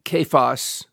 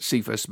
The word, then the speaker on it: Cephas: Kephas, or you may used to pronouncing it Cephas, but anyway, it's Aramaic, and Peter is Greek for rock.